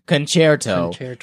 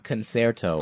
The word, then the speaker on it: concerto: And the second EE from Brian is, as the resident classical musician, I thought I should inform you that concerto is, in fact, pronounced concerto, not concerto. As I said it.